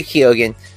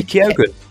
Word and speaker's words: Keoghan: Barry Keoghan, which I know that's not how you say his name. It's Barry Keoghan. – You are correct. – You do hit the O.